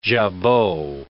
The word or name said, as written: jabot